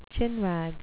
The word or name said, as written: chinwag